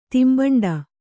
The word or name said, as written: ತಿಂಬಂಡ